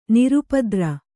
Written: ನಿರುಪದ್ರ

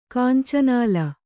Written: ಕಾಂಚನಾಲ

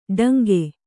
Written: ಟಂಕೆ

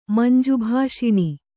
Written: ಮಂಜುಭಾಷಿಣಿ

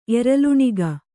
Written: ಎರಲುಣಿಗ

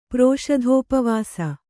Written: ಪ್ರೋಷಧೋಪವಾಸಿ

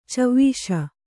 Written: ಚವಿಸು

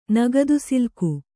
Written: ನಗದುಸಿಲ್ಕು

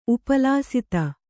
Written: ಉಪಲಸಿತ

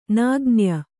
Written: ನಗಣ್ಯ

